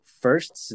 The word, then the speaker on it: Firszt: I am here with Neil Firszt. Is that how you pronounce the last name? – Yep, just like the number one, man.